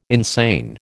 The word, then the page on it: insane: American English